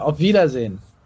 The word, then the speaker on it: auf Wiedersehen: In German, uh, auf Wiedersehen. – All right, then. I guess then in this case, it's Ovidazen from me to you then.